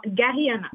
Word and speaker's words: Garyana: Et aussi avec Garyana Jean-Louis, c'est bien comme ça qu'il faut prononcer Gary ou Jarry? – Non, Garyana.